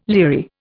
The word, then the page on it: leery: Indian